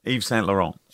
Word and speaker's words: Yves Saint Laurent: Yves Saint Laurent.